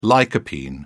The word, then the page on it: lycopene: British English